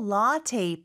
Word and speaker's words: latte: I'll have a small latte, please. 자, 일단 latte는 latte이겠네요, 그렇죠? Mm-hmm. 발음이 조금 다르네요, 그렇죠? 영어로는 latte 이렇게 발음을 합니다.